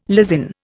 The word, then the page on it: lysin: Indian